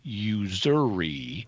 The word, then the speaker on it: usury: And that's unexpected, because normally in English pronunciation, when an S is intervocalic, meaning it is between two vowels, like S in usury is, it's between two U's, you pronounce it as Z.